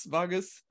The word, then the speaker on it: vagus: Vagus, vagus. Oh, some people say vagus, either short or long A sound.